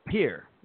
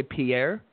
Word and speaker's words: Pierre: It's peer. Just one syllable. Peer. – Oh, it's peer. Like. – Yeah. – Peer. Okay. – Yep. – See, I was gonna say Pierre, and I didn't want to like.